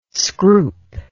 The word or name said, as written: scroop